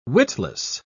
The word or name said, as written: witless